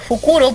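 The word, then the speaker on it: Fukuro (梟): Que Fukuro, búho, se pronuncia como O al final de la larga, en lugar de la O. Corta palabra de Fukuro, que es bolsa.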